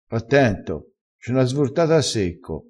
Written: svortata

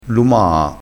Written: lumaa

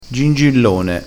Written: gingillone

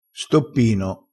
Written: stoppino

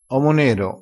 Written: omo nero

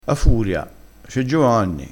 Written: ha furia

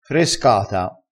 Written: frescata

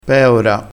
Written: peora

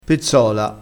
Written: pezzola

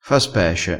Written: fa spece

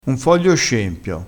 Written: scempio

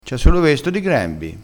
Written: grembio

